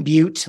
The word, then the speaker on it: Beute: My name is Ethan Beute. Last name is spelled B-E-U-T-E.